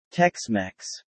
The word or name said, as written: Texmex